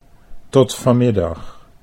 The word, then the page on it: tot vanmiddag: Dutch